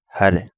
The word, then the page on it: Hare: Kapingamarangi